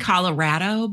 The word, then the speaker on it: Colorado: Also, Colorado, it is like pronounced Colorado, but okay. – Oh, damn. It's the red estate. – It is. When I went there, that's how everyone who lives there says it.